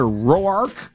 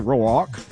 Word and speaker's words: Roark: The Cubs are in Cincinnati, a 5:40 start tonight on the score with Kyle Hendricks going up against Tanner Roark. Or Rourke, depending on how you say it, Roark.